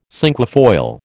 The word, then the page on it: cinquefoil: American English